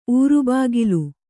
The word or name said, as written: ಊರುಬಾಗಿಲು